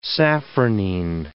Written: safranine

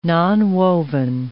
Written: nonwoven

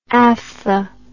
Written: aphtha

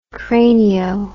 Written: cranio

